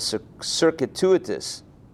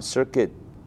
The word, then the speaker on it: circuitous: Now, if when he led them around in a circuitous, I'm pronouncing it wrong, circuitous. I think I was pronouncing it wrong. Say it again.